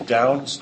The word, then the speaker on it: Downes: My Twitter ID is Downes, D-O-W-N-E-S.